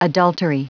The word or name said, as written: adultery